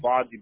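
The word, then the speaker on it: N'Fagie: It's N apostrophe F-A-G-I-E. – Got it. So is the N silent? – Nah, everybody just called me Faji, but my real name is Nfaji.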